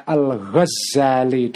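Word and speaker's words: Al-Ghazali (الغزّالي): Eh, ada yang membacanya Al-Ghazali dengan tasdid.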